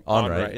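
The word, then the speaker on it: Onrait: You know, these guys were talking about something last night, and then I heard it on TSN on Jay, what is it, Onright? How do you say his last name? – Onright, yeah, yeah.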